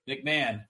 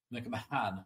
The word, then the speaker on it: McMahon: McMahon. Like Vince. It's spelled the same way. – McMahon.